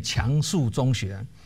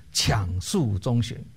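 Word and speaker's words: 強恕中學: 台北市有一间中学叫做大家都念强恕中学，其实正确的发音是强恕中学，因为这个强是勉强的强，是勉强自己的意思了。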